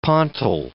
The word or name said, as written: pontil